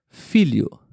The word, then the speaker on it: filho: Mas você fala um pouquinho mais rápido. Filho, filho o som sai praticamente idêntico.